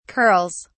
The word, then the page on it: curls: British English